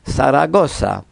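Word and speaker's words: Zaragoza: Hispanoj prononcas la konsonanton zo, kvazaŭ so. Latinamerikanoj simpligas ĝian fonetikon kaj zo ili prononcas klare kiel so. Saragosa.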